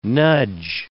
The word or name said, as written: nudge